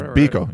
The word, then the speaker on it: Biko: Is Biko. Yeah, B-I-K-O.